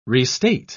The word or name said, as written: restate